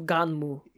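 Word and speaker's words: Gunnm: aka Gunmu? Is that how you say it? Because it's literally, there's no like extra vowel at the end. – That's a weird romanization they did. It translates to Ganmu.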